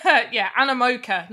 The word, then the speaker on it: Animoca: Now, obviously, Sandbox is like their parent company is Animoco Brands. – Brands. – Yeah. – Animoco, whatever. – Yeah. I always say it wrong. Animoca, not Animoco.